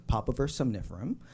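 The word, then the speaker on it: Papaver somniferum: So you can't talk about the history of heroin without talking about the story of this humble flower, Popovir somniferum. Did I say that right? Yes. Or more commonly known as the opium poppy.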